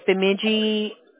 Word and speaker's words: Bemidji: And that's Bemidji.